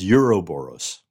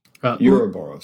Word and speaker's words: Ouroboros: I think it's the original root language is, I believe, Greek. – It is. – Okay. – And which there's an alternate spelling that doesn't have the O at the beginning of it. So I think the pronunciation is Ouroboros.